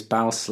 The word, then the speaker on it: Baus: And then we also met the guys from, I really hope I'm saying this right, Baus Brothers. – Baus, I think it's Baus like mouse, not...